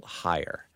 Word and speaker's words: Heyer: Look up, just do a search for Walt Heyer. Walt, W-A-L-T, and then Heyer is spelled H-E-Y-E-R. H-E-Y-E-R.